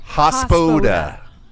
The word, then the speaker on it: Hospoda: At Hospoda. – That's probably the first time you've said it right. – I wanted to say Hospoda. – But then someone taught you the trick to think of hospital.